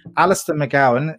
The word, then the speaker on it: Alistair McGowan: You suggested in the beginning a book, and I've listened to your podcast on your website with Alistair McGowan, if I say it correctly this time.